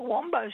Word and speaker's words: Wambo: Other one, we have a Wombo Street, which is spelt Wambo Street.